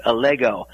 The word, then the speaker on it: Allego: You did have the name pronounced correctly. It's Allego.